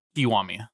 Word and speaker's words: Kiwami: Kiwami.